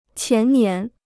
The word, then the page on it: 前年: qián nián